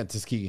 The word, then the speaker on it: Tuskegee: Yeah, Tuskegee.